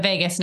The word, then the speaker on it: vagus: Yeah, I mean, Carrie was saying that humming actually benefits, is it the vagus nerve? How do you say that? – The vagus nerve, yeah.